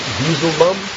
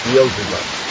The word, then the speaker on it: Beelzebub: At one end, then you have, how do you say, Beezle Bum? Right? You know Beezle Bum? No one knows Beezle Bum? Huh? No, Beezle Bum is one of the names for Satan. – Beelzebub.